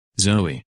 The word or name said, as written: Zoe